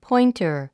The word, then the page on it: pointer: pɔ́intər; ポインター